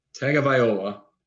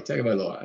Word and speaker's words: Tagovailoa: Tagovailoa, and exciting Tagovailoa. Sorry, Tagovailoa. I always mispronounce that.